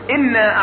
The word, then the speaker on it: إنا: فبعض الناس يمر مرور الكرام على حرف النون في قوله تعالى إنا، فيقول إنا أعطيناك، والصواب كما عرفنا إنا أعطيناك الكوثر، هكذا. فالنون المشددة تنطق كما اتفقنا بغنة، تنطق كما اتفقنا بغنة، وحكمها كما يقول علماء التجويد الإظهار بغنة، الإظهار بغنة.